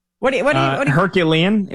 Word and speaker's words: Herculean: So, like, Fernando Mendoza would have to do, like, be Hercules, Heroclean. How do you say that word? – Herculean. It would take a Herculean season.